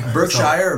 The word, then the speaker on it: Berkshire: Berkshire Hogs. – Yeah. Berkshire or Berkshire? You could say either. Okay. If you're from Iowa, it's Berkshire.